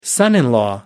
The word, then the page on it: son-in-law: American English